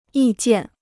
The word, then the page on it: 意见: yì jiàn